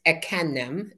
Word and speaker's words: Ekanem: My full name is Ekanem. That's how you say it.